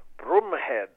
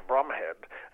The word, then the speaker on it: Bromhead: play Broomhead, not Bromhead, so he was told by the family. It's mispronounced in the film, but an officer and a gentleman who, with Stanley Baker's charred, they defend this indefensible position.